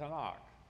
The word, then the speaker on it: Tanakh: "You're talking about the Tanakh." So it's just T-N-K with a couple of A's stuck in there to be able to pronounce the word, the Tanakh.